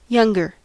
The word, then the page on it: younger: American English